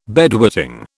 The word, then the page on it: bedwetting: British English